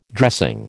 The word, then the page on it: dressing: American English